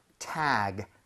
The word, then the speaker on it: tag: 얘는 tag 아닙니다. G는 CK라는 스펠링보다 부드러워요. – 그쵸. – 그렇게 되면 tag 하지 마시고요. tag, Tag. – tag. 이런 느낌으로 부드럽게 한번 해보세요.